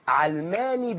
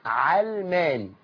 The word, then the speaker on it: علماني: الكلمة في الأصل هي وضعت في المعاجم مؤخرا، لكن هي في الأصل ما تنطقهاش بكسر العين، هي مش من العلم زي ما يخدعنا البعض ان علماني يعني عالمية او يعني علمية. علماني بفتح العين، لا لها علاقة بالعلم ولا بالعالمية، اسمها علماني، علماني بفتح العين.